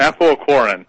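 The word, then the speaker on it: apoaequorin: It's called apoquin, or what is it? – Apoquarin.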